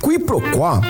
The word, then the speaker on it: quiproquó: Q-U-I-P-R-O-Q-U-O com acento agudo. Quiproquó, também informalmente pronunciada como quiproquó, é a ação de confundir uma coisa com outra, ou o desentendimento decorrente dessa confusão.